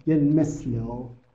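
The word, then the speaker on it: Hjelmslev: لوی یلمزلو البته اینو بهتره که سه بنویسم یلمسلو لوی یلمسلو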